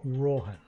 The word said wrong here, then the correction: Rohmann: Roman. – Oh, God. Spell it again. – R-O-H-M-A-N-N. – M-A-N-N.